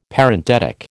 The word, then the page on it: Parenthetic: American English